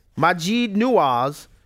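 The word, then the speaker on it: Maajid Nawaz: Majid Nawaz, who threatened to sue them.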